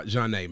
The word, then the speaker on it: Zhané: So, Van, your highlights from Pronounced by Jah-Nay, man.